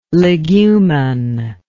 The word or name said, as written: legumin